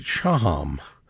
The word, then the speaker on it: Shaham: Although, interestingly, when Orly pronounces her name, she pronounces it Shaham.